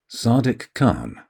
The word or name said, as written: Sadiq Khan